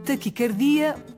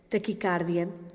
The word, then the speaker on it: taquicardia: Apesar de ser muito frequente a pronúncia com A aberto, taquicárdia, no domínio da medicina, segundo a etimologia da palavra, ou seja, segundo a origem da palavra, ela deve ser articulada com o A fechado, taquicardia. Porquê? Porque esta palavra provém do grego taquis, T-A-K-H-Y-S, que em grego significa rápido, e o nome grego, cardia, que significa em grego coração.